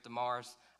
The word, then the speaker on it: Damaris: The last person there, Damaris, it's pronounced Damaris, Damaris. I've heard other people pronounce it different ways. For the sake of my lack of being able to pronounce stuff, today it's going to be Damaris.